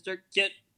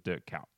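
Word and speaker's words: Kuyt: might be hard for you English uh, journos to pronounce, but it's dirt cute."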